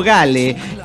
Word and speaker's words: Gail: Bueno, por cierto, pirata, ¿alguna vez preguntaste cómo se pronuncia mi nickname? Solo Gale, que es... yo le doy un toque así, Gale. International Associated Gale. Sí, Gale, la chingada, muy bien, es Gale. Es diminutivo de Gabriela, así como se lee Gale.